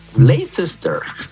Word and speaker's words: Leicester: Vámonos hasta Inglaterra, donde el Leicester City, Leicester...